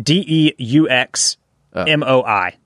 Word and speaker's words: DeuxMoi: It's called DeuxMoi. I don't think so. DeuxMoi. – DeuxMoi. So this is spelled D E U X M O I.